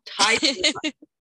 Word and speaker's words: Tituba: Tituba. – Not a fan of the word tit. – Tituba. Fair enough. – Tituba. I'm going to pull the Philly out. Tituba. – There you go.